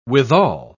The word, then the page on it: withal: British English